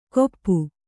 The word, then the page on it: ಕೊಪ್ಪು: koppu; Kannada